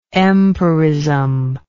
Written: empiristic

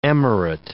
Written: emirate

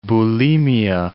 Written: bulimia

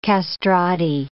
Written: castrati

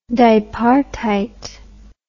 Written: dipartite

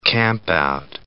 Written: campout

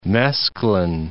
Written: mesclun

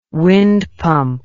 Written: wind pump

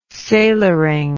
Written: sailoring

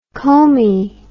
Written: comby

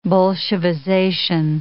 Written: Bolshevization

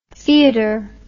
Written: fiador

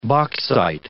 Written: bauxite

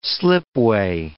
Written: slipway

